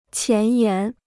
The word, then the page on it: 前沿: qián yán